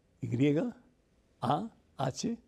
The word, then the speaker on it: Yahweh: ellos argumentan que el verdadero nombre y la forma de pronunciar el nombre de Dios es con el nombre que lo pronunciamos nosotros, que es Y, a-H-W-E-H, que significa Yahweh o significa yo soy el que soy.